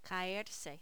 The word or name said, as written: caerse